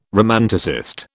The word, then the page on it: romanticist: British English